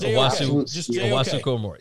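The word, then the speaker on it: Owusu-Koramoah: The Owosu Oromoh, and I know I'm butchering that name, but that's Owosu Komor. Yeah, J-O-K. – J-O-K.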